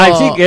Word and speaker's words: Leipzig: Es Leipzig, es lo que nos dijeron que teníamos que decir.